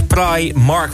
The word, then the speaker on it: Primark: Primark spreek je officieel uit als Primark, waarbij je de I uitspreekt zoals in ice.